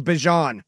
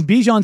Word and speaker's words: Bijan: Guy and the color guy were saying Bajon. – So somebody got the pronunciation guide, and Bijan says everybody's called me Bijan since I've been Bijan. It's Bijan's fine.